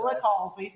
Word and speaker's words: Licalzi: Licalzi.